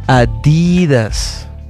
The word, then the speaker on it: Adidas: Lo que pasa es que tiene que haber un, dicen los alemanes, tiene que haber un énfasis en el D. Adidas. Así se pronuncia Adidas.